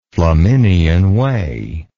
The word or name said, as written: flaminian way